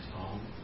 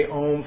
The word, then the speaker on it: Om: Proper way to chant Om is, if you were to say matric, M-A-T-R-A-C, or units of time, the O should have equal time as the M. Some people like to have a long O and a quick M at the end. Like, Om. It's really, if you were to say, mentally you would say Om for like 6 seconds.